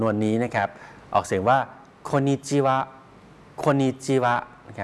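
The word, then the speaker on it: โ คนิ จิวะ (こんにちは): คนญี่ปุ่นเขาจะออกเสียงคล้ายๆเป็นเสียงตัวสะกดออกเสียงเร็วนะครับรวมกันเร็วโคกับอึก็เลยออกเสียงว่าคนครับคนเขียนสรุปเป็นเสียงภาษาไทยไว้ให้แล้วนะครับแล้วก็ขีดเส้นใต้ไว้ให้แล้วว่าออกเสียงรวมกันนะครับออกเสียงเร็วครับโคนิจิวะ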